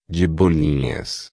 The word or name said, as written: de bolinhas